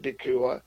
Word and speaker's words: DeCuir: And remember, you can write Prophet DeCure. That's Pastor DeCure. My name is spelled DeCuir.